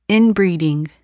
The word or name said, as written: inbreeding